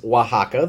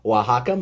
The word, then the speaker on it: Oaxaca: Yes, this is Oaxaca, the brand, just like the city where mezcal is produced. Spelled differently, though, pronounced the same. Oaxaca Mezcal, yes, this is their Espadín offering.